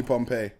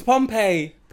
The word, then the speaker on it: Pompeii: I'm Pompey. – That's what I'm saying. – Why? – because Google said so. That's it. – No Pompeii, fam, trust me. It's Pompeii. – Pompeii. – Pompeii.